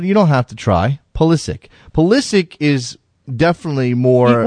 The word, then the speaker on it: Pulisic: Coming to you said Pulisic, by the way, Pulisic is how you say his name.